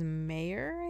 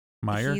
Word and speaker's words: Meier: And this guy, his name's Mayor, I think? Anyway. – He wasn't the mayor. – That's the way it's spelled. M-E-I-E-R. – Gotcha. Meyer? – He's Meyer, maybe.